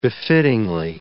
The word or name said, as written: befittingly